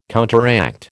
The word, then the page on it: counteract: American English